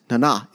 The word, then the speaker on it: Nana: Uh, it's Nana. It's French, and it's it's really great, and it reminds her of of family, and that's how she likes it pronounced.